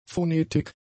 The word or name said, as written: Phonetik